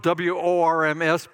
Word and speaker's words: Worms: It's spelled W O R M S, but pronounced as if it's a V, Worms.